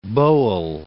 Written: Bowell

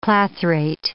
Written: clathrate

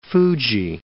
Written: Fuji, Mount

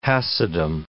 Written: Chassidim

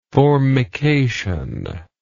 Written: formication

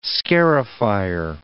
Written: scarifier

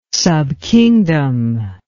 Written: subkingdom

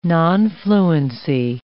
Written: nonfluency

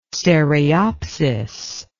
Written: stereopsis

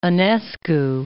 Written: Enescu

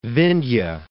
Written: Vindhya Mountains

